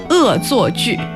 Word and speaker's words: 恶作剧: 恶作剧。